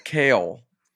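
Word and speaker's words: Cale: Kale. It's C-A-L-E, but it's pronounced K-A-Y-L.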